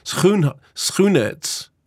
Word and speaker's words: Schoenaerts: It's not a R sound, it's a H sound. Schoenaerts.